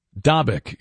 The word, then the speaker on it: Dabiq: Dabiq is, by the way, the correct pronunciation.